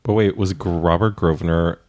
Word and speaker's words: Grosvenor: Grosvenor. – Yeah, the S is silent? – Yep.